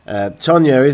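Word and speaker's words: Toña: Tonya is the beer of Nicaragua. And it's actually, I've never told you this, but the reason T-O-N-A is pronounced Tonya, it has a cedilla over the end.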